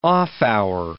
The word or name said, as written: off-hour